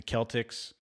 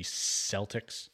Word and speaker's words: Celtics: It's got the Irish feel for, you know, Boston um, should be pronounced the Celtics, but for whatever reason, they were just like, nah, let's just not have a hard C, let's have a soft C and have it be Celtics.